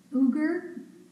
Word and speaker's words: Uighur: and please forgive me for not pronouncing this correctly, it's Uyghur, U-I-G-H-U-R, Muslims, and members of the Falun, F-A-L-U-N, Gong religious group.